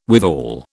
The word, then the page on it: withal: British English